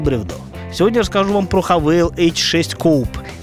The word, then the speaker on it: Haval: Меня специально проинструктировали насчет того, как правильно произносить название машины. Иначе бы я на голубом глазу сказал так: Haval H6 Coupe. Ну, то есть точно так же, как и любой другой житель нашей страны.